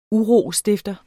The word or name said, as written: UROSTIFTERNES